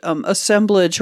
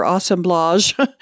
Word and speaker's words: assemblage: There's something called, well, depending on how you pronounce it, assemblage or assemblage.